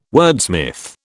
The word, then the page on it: wordsmith: British English